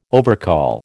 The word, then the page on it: overcall: American English